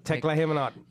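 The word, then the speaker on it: Teklehaimanot: Well, how do you say his name? – Tekla Hemanot.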